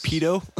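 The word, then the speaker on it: Peyto Lake: Oh, I believe it's pronounced Pedo. – It's pronounced Pedo Lake.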